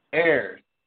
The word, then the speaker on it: heir: Air. – The brightness. – It's called air. Like you are heir. You know. You're going to inherit something. Air. The H is silent.